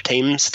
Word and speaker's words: Thames: But anyway, the Thames and Thames, Thames.